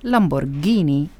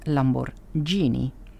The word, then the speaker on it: Lamborghini: De H tussen de G en de I zorgt ervoor dat je Lamborghini zegt en niet Lamborghini.